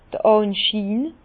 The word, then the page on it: d'r Ànschiin: Bas-Rhin; Herrlisheim